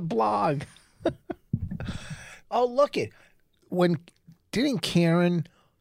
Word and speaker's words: Kerryn: It is. It's Karen. – It's Karen. – Oh, I always been getting that wrong. – It's because it's spelled K-E-R-R-Y-N. You always say Kieran.